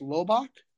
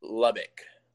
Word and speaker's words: Lubbock: So how far is is it pronounced Lobach? – Lubbock? Lubbock.